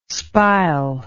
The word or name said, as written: spile